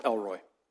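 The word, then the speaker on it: El Roi: I kid you not, it's pronounced Elroy.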